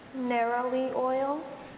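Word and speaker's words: neroli: Narrowly oil? – Narrowly oil.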